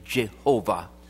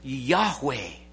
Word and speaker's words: Yahweh: It's the Hebrew form of what we would pronounce today, Jehovah. In the Hebrew characters, there wouldn't have been the vowels. So Yahweh.